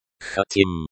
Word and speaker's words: хотим: Хотим. Хотим. The O sound is like the O in hot. The I sound is like the I in machine.